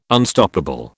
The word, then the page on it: Unstoppable: British English